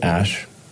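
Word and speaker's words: Æ: So, I mean, it's just X, the letter X, and then the AE is like pronounced Ash.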